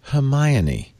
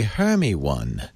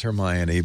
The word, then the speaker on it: Hermione: She said, it is pronounced Hermione. Many people come to me and say, Hermy one. – That is wow. Not how it's pronounced. It's Hermy Hermione.